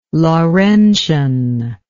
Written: Laurentian

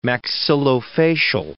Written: maxillofacial

